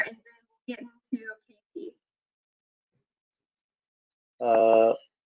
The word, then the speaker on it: KHETEE: Yeah, KHETEE.